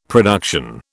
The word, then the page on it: production: British English